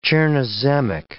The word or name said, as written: chernozemic